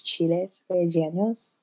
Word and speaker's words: chiles rellenos: I had the best, I'm going to say it, but like, I feel like I'm going to butcher it, but it's chiles rellenos. – No, yeah, you said it perfectly.